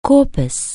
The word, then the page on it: corpus: anglais